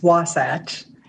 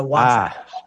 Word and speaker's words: Wasatch: Almost. It's Wasatch. It is a mountain range in Utah that is part of the Rocky Mountain Range, but it's in the Utah portion. So Wasatch.